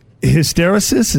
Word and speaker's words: hysteresis: Hysteresis, is that the correct way to say it? I said it hysteresis. – Wrong. Hysteresis. Okay.